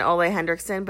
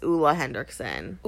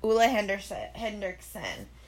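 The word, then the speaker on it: Ole Henriksen: It's not, I've always been pronouncing it Ole Hendrickson, but it's not. It's Ulla Hendrickson. – Ulla Hendrickson.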